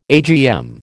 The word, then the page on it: AGM: American English